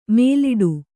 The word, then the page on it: ಮೇಲಿಡು: mēliḍu